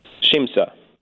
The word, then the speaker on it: Siamsa: I am not sure how to pronounce S-I-A-M-S-A. – Shimsa.